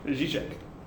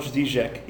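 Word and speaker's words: Žižek: Zizek. – What's this guy? – Like Slavoj Zizek. He's the Croatian public intellectual. – How do you spell that? – Z-I-Z-E-K. – Zizek.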